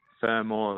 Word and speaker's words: Fermor: Is it Fermor or Fermor? – Fermor, the first phrase.